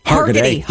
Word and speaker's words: Hargitay: But the Emmys will go down Monday, September 14th, NBC, Taylor Swift's bestie, Mariska Hegarty. Is that how you say it? – Hargitay. – Hargitay? – Yep.